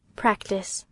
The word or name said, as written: practice